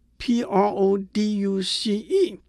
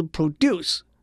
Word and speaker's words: produce: P R O D U C E 作为动词是 produce，是制造、产生的意思；作为名词是 produce，是农产品的意思。